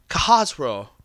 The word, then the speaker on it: Khosrow: Kahazro, Kahazro, Kahazro.